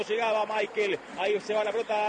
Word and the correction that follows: Mikel: ¿Cómo le decimos? ¿Michael o Mikel? Nicolás Álvarez, usted que es especialista en nombre, se escribe Mikel, ¿cómo le digo? – Decile Mikel. – Entonces le digo a Mikel, usted me enseña y yo aprendo.